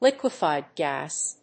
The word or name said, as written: liquefied gas